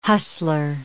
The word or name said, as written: hustler